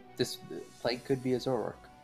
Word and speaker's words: Zoroark: It's like Zoroark or something like that. Weird. – Zoroark.